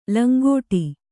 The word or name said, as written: ಲಂಗಡ